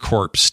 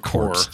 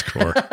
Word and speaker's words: Corps: Finally, Panzer Corps 2. Or Panzer Corps. It's not Corps. Sorry, it's Corps. – Corps.